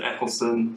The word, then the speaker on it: Eccleston: And then went back and watched, was it Eccleston? Is that how you say it? – Yeah, his last name.